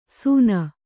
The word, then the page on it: ಸೂನ: Kannada